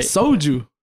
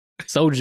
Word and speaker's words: soju: I wonder if you're even pronouncing it right. – Soju. – Soju. – You know, it sounds like I'm saying soldier.